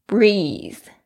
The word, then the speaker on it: breathe: exercise 运动 is as as 就是说就像什么什么一样，所以呢他今天呢来比较的是 breathing，是这个呼吸这件事情，它的原型是 breathe，注意一下 TH 的发音，轻轻咬住舌尖发出有声的这个 L 的声音， breathing 就是这一件事情。